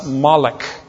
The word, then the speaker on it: Molech: And so they would take the proper name Milcah, and they would take the Hebrew word Boshet with the O, long O, and the short E, and they'd substitute the two vowels into the name Milcah, thus Molech.